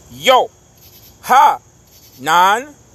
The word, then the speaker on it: Yohanan: À titre d'exemple, Jean de l'hébreu Yo, Ha, Nan, Yohanan s'écrit Y-O-H-A-N-A-N, que les gens ont appelé maintenant Jean là.